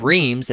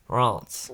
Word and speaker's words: Reims: That's how you pronounce it. Reims. Even though it's spelled Reims, it's pronounced Reims. – Reims. – Yeah. It's very nasally sound. – It's like somebody just broke your nose and you're trying to find a local Ralph's. – This is after me listening to how to pronounce what looks to be a word spelled Reims. – It's Reims. – Actually pronounced Reims. – It's Reims. – There's not even an M in it when you pronounce it the correct way.